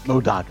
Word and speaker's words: MODOK: MODOK.